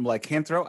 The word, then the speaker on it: Lycanthro: Today, I am joined by James from Lycanthro. I hope I remembered your name. Did I? – Yeah. That's how awesome. You pronounce it. Yes.